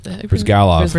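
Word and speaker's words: Bryzgalov: But didn't they bring in... Brizgali? Is that... – Brizgalov.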